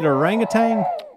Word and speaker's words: orangutan: This whole time I've wanted to say tang. – I've been saying tang for 57 years. – Yeah. – I have too. – Orangutan. – Orangutan. – Y'all have been naming it after the astronaut drink. – Okay. – I think that's an Australian way you say it. It's really orangutan. – Either one's acceptable. – No, it's tan. It's spelled tan.